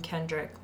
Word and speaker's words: Kendrick: So it is now Lauren M. Kendrick, like Kendrick Lamar.